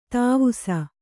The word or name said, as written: ತಿವಿಸು